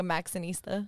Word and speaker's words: Maxinista: Did I say it right though? Max and Easter? Like, no, not an Easter. It's not Easter like the holiday.